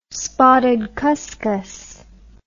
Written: spotted cuscus

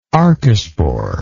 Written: archespore

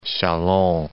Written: Chalon

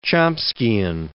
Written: Chomskian

